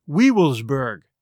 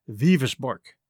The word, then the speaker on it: Wewelsburg: The word is spelled W-E-W-E-L-S-B-U-R-G, which looks like Weevilsburg. But I checked the pronunciation, and it's pronounced Vivisborg.